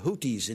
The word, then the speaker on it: Houthi: It would look like it was Houthi. It's T-H. Houthi. – Houthi. – You say Houki.